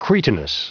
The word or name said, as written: cretinous